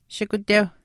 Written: shikuteuh

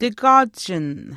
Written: tikwaachin

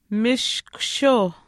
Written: mishkushiuh

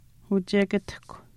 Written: uchekatahkw

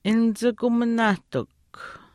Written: nichikuminaahtikw

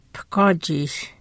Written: pihkwaachiish

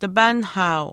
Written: tipaanihaau

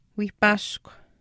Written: wiihpaashkw

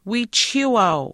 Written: wiichihiiwaau

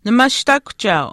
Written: nimaashtaakuhchaau

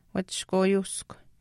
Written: wachishkwaayuuskw

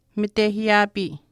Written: mitehiiyaapii

